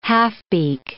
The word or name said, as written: halfbeak